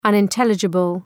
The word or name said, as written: unintelligible